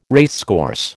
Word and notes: racecourse: American English

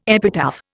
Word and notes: epitaph: Indian

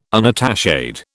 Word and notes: unattached: British English

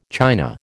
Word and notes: china: American English

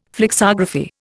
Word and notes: flexography: Indian

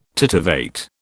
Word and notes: titivate: British English

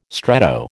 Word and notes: stretto: American English

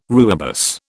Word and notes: rooibos: British English

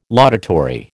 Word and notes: laudatory: American English

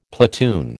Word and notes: platoon: American English

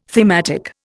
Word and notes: thematic: Indian

